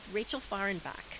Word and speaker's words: Rachel Fahrenbach: I am here today with Rachel Fahrenbach.